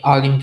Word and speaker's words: Alim: Yeah, Alim program, yeah.